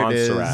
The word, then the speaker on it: Montserrat: I think it's Montserrat, no silent T. – Ooh, right, like the font. – There Montserrat. – It is. – Montserrat.